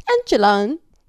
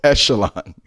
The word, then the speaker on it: echelon: And now I know how to pronounce enchilon. – Echelon.